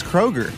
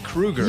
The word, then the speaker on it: Kroeger: Chad Kroeger, I believe, is how you say his name now, remember? – Oh, no, it's Kroeger. – We had it on the show. It's Chad Kroeger.